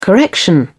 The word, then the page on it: correction: British English